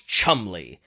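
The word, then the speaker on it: Cholmondeley: And get a load of how this guy's name is spelled. So his name is Charles Chumley. How would you expect his name to be spelled? – Chumley. – Oh, I would expect that too, but no. Chomondeley.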